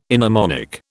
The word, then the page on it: inharmonic: British English